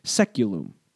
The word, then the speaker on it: saeculum: I'll be using that word quite a bit in this podcast, so from here on out, I'll be sticking with the anglicized pronunciation seculum.